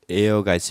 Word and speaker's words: heir: 자, 이거와 같은 말이 에어가 있습니다. 써주세요. H-E-I-R. 물론 H는 무음이고 읽을 때는 에어라고 읽습니다.